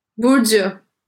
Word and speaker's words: Burcu: Burcu.